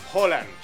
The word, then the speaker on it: Haaland: Bueno, Alfredo González, comentarista de Fórmula Deportes, ¿cómo se pronuncia? – Holland. – Holland. – Holland. – Es con O. – Y es rápido, es Erling Haaland. – Haaland.